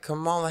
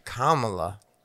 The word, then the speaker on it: Kamala: I've always said Kamala Harris. Is that wrong? – It doesn't sound wrong to me. – Somebody said Kamala? – Kamala.